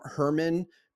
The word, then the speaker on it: Hermon: And he talks about, he said he rhymes it as Mount Herman because it rhymes with sermon.